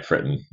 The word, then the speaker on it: Fritton: First one's right, Fritton.